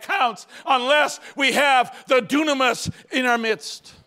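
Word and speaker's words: dunamis (δύναμις): In Greek, it's pronounced dunamis.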